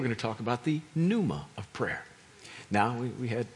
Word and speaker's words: pneuma: This one is phonetically not alliteration, but it is at least grammatically, in that it's one of those silent P's. And pneuma means this. It's the Greek word for spirit.